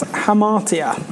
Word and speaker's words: hamartia: And in Greek, it's hamartia. I think I'm saying that right. Hamartia. Hamartia. Yeah, there we go.